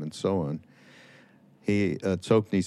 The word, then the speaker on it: Tsoknyi: Uh, so there's a um, a great Rinpoche named Tsoknyi Rinpoche. Soki Rinpoche is how it's supposed to be pronounced, I think.